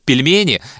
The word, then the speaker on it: пельмени: Да, там очень топорно сделан видеоблог, конечно, но то, как она произносит пельмени, это же прям, ну, такая гимнастика.